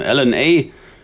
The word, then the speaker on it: LNA: LNA, ausgesprochen wie DNA.